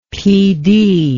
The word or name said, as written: Pee Dee